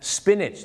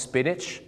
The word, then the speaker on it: spinach: ไม่ใช่สปีแนทหรือสปีนาทนะฮะ spinach นั่นเองครับออกเสียงว่า spinach